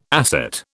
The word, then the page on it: asset: British English